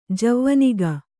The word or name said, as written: ಜವನಿಕೆ